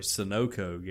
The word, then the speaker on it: Sunoco: Witnesses told deputies that Fulmer confronted a victim near a Sunoco gas station, pronounced it correctly, in Fairmount, saying she didn't want to hurt them and motioning like she had a weapon.